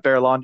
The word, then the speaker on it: Baerlon: Barilon.